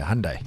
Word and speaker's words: Hyundai: Hyundai. It's supposed to be like Monday. Hyundai.